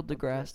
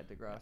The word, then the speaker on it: deGrasse: Neil deGrasse Tyson. – Yeah, deGrasse.